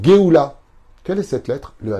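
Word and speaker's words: Geoula (גאולה): La libération, la rédemption, comment on le dit en hébreu Geoula. C'est un truc de fou. Geoula, ça s'écrit Gimel, Aleph, Vav, Lamed, He.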